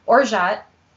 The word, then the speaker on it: orgeat: So orgeat, um, if you, I, I actually didn't, I, I've been mispronouncing it for years. It's O R G E A T.